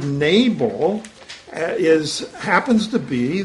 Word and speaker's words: Nabal: The Hebrew word is Nabal, or you might pronounce it Nabal, N-A-B-A-L.